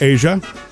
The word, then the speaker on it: Aja: And she says, it's pronounced Asia. – I'm like, that's beautiful. And it's spelled A-J-A.